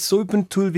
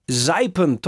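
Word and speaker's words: zuipen: Es das heißt nicht Sulpentul wie äh, tot wie Grüpen, sondern es heißt Seipen tot wie Kreipen.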